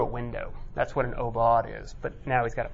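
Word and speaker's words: aubade: So here's Obaud is how you pronounce it.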